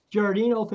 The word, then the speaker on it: Giardino: I, yeah, So, anyway, let's bring in uh, Sheriff Richard uh, Giordano. Giardino. Am I saying that right, Sheriff Giardino? – Giardino, thanks for having me.